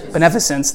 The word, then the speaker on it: beneficence: How do you say that? Beneficence. There we go.